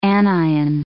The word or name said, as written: anion